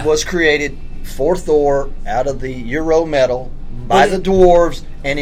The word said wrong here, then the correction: Uru: I thought it was Uru.